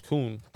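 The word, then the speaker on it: Kuhn: No, actually, I want to know how to say that correctly. – You did say it right. – You think though? You don't think it's Herkun? – Mm. It's Kun. – Or Herkun? You think it's Herkun? It looks like it. It looks like that. But. Yeah, right there. German Nazi activist. You're right, Kun. Herkoon, you're right. That's how you say it.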